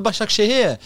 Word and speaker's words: Başakşehir: It's Istanbul Başakşehir.